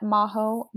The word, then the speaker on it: Majo: And I'm available on Instagram at Majo Molfino. My first name is spelled M-A-J-O.